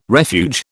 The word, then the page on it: refuge: British English